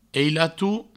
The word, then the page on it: ailato: eilatou